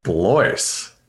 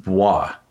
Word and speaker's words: Blois: And he said like, Blois? Like you don't know French, do you? I was like, no, I do not. Which apparently Blois, spelled B-L-O-I-S, is pronounced Bois.